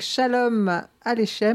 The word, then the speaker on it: Shalom Aleichem: Une petite pause musicale avec Shalom Aleichem.